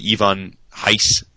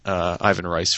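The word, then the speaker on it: Ivan Reis: Ivan Heiss, Ivan Rice, for those who don't want to say his name the proper way.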